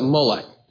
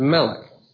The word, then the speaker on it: Molech: Because vowels have been placed in there to pronounce it Molech. But you can also pronounce the same exact word, Melech, which means a servant of.